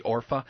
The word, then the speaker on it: Orpah: One, I love butchering this one, one named Orpah, I call her Orpah, her name's really Orpah, and the other Ruth.